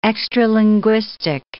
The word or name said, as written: extralinguistic